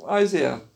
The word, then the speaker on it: Isaiah: Isaiah diyen de var. Isaiah da diyen var. Isaiah doğrusu yani. Herkes öyle diyor.